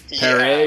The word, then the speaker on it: Paré: Yeah, it's P-A-R accent E. So it's Paré, yeah.